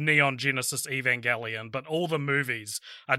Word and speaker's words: Evangelion: Yeah, I would say the Evangelion series. – Evangelion. – Yeah. And I appreciate your pronunciation there. Evangelion. Are kind of the two ones, but it's that hard G.